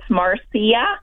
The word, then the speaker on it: Marcia: Well, I don't know. I, I, I do answer to all of them, but it's Marcia. – Yes. Is the correct, um, the way it's spelled, but everybody calls me Marcy.